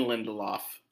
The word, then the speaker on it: Lindelof: Damon Lindelof.